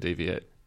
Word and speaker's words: DEV1AT3: Lifelike and Deviate.